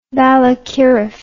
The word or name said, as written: balakirev